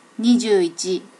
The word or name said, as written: nijuichi